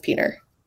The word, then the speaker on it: Piotr: Some just go Peter. Some say Piotr. Some say Petra. – Yeah, Piotr is, I think, the correct way to say it. But everyone just says Peter.